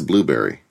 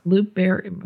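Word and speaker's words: Blubrry: Blurbery, B-L-U-B-R-R-Y. I may not be pronouncing that right, is another. But I think it's Blueberry. – Blueberry? – Yeah. – Blueberry.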